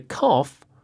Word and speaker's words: cough: So there were two O-U-G-H words there. Cough, C-O-U-G-H. And roughly, R-O-U-G-H-L-Y. Roughly means about. So George had a cough for about, or roughly, 3 months. – But the pronunciation of these two words is different. – Mm. – The O-U-G-H in cough sounds like off. Off, cough. – And the O-U-G-H in roughly sounds like off.